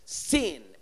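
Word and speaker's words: Sin (σύν): The way you would spell it in English is S-U-N, like it's sun, but the U is an E sound in Greek. E. So it's like saying S-E-E-N. So write down S-U-N equals, in, and then put in quotes so you know how to pronounce it. Sin, S-E-E-N.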